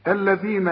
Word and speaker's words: الذين: الذين آتيناهم، ما فيش أبدا مشكلة في نطق هذه الكلمة عند الجميع لأنها كلها حروف مرققة، لكن طلعنا لسانك في الذال.